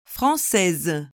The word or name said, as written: francaise